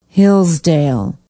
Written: hillsdale

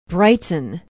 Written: brighton

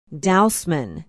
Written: dousman

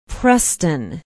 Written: preston

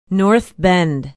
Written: north bend

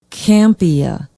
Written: campia

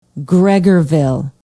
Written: gregorville

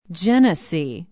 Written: Genesee

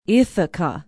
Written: ithaca

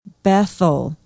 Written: bethel

